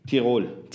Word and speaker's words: Tyrol: And you pronounce it Tyrol? – Tyrol. – Tyrol. – Yeah. – Tyrol. Yeah. – Okay. Tyrol. – Tyrol. – Would be like the dialect.